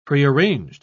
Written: prearranged

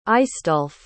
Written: aistulf

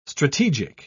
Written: strategic

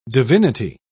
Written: divinity

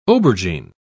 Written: aubergine

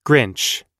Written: grinch

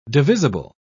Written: divisible

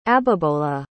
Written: abibola

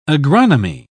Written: agronomy

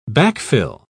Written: backfill

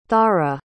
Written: thara

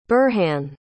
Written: burhan

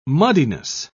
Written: muddiness

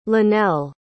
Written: lanelle

